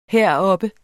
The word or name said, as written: heroppe